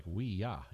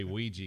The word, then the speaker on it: Ouija: So because to actually pronounce it would be like we ya, you know, but it's we just say Ouija because we're a bunch of stupid Americans.